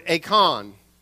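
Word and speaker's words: eikon (εἰκών): In the Greek Old Testament, the word that they translated this image into, made in God's image, is the Greek word eikon. We would spell that E-I-K-O-N in transliterated Greek, if you will.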